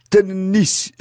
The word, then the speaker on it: tennis: Et là, Larousse juge même utile de signaler qu'on prononce tennis et il y met trois N pour bien faire comprendre qu'il faut le dire avec l'accent anglais.